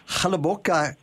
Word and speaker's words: Hluboká: And next day, of course, you're right there to visit Chalaboka Castle. Did I get that right, Michaela? – Yes, very good pronunciation again.